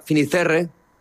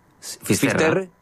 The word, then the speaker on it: Fisterra: Entonces llegó a Finisterre, ¿se dice así? – Fisterra.